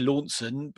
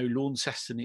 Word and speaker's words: Launceston: I've actually been to Launceston, and I have said that right to our Cornwall listeners. We would be used to Launceston, but no, Launceston it said.